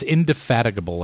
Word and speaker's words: indefatigable: I love your use of indefatigable, indefatigable, ingatifatable. Yeah. – However that's pronounced. – You said it correctly, and I can't pronounce it. I think it's indefatigable, like you said.